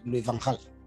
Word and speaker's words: Louis van Gaal: like Louis van Gaal. – Yep, very good pronunciation, by the way. – I try to pronounce it in a Dutch way, yeah, because I have many Dutch friends and I try it.